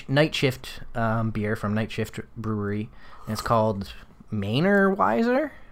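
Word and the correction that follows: Mainer Weisse: Mainer Weiss. – Mainer Weiss. – So two words, Mainer, like you're from the state of Maine, and then Weiss, like the kind of beer.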